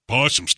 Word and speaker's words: possum: Possums can't stand the stuff. It's pronounced possum, Roger.